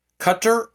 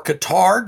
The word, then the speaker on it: Qatar: He visited Saudi Arabia, Qatar or Qatar, depending on how you want to pronounce it. I usually call it Qatar, and the United Arab Emirates or UAE.